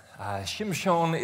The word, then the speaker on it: Shimshon: Shimshon is the pronunciation in Hebrew for Samson.